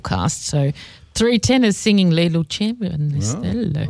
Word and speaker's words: Le lucevan le stelle: Is that roughly it, or Le Stellen? – Le Lucivan Le Stelle. – And, Michelle, you're proper Italian. Pronounce it properly for me once. – Le Lucevan Le Stelle.